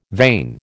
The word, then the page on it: vain: American English